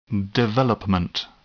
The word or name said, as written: development